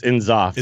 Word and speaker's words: N'Zoth: It's I think it's Inzoth. – Is it Inzoth? Is that how you'd say it? – Yeah. – All right, it's like NSYNC.